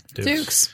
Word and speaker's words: Dux: In a, possibly a missed opportunity moment, Chun-Li faces a guy right directly before he goes up against Frank Dux. – Dukes. It's spelled like ducks, but it's Dukes.